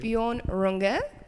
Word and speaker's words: Björn Runge: Björn Runge. Did I say that correctly? – Perfect.